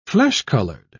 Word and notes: flesh-colored: British English

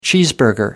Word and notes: cheeseburger: American English

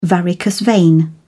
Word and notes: varicose vein: British English

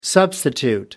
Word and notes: substitute: American English

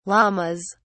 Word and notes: Lammas: British English

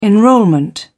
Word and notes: enrolment: British English

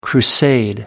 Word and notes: crusade: American English

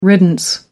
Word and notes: riddance: American English

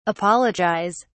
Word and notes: apologise: British English

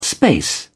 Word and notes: space: British English

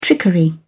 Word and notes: trickery: British English

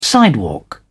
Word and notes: sidewalk: British English

